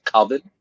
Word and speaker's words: Coven: Anna says you're pronouncing it wrong. She's saying it's Coven.